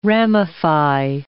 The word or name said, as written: ramify